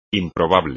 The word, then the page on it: improbable: espagnol